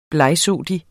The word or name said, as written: blegsottig